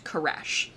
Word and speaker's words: Koresh: The first being King David, and the second, Cyrus, which in the traditional Hebrew would be spelled and pronounced Koresh.